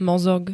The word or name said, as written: mozog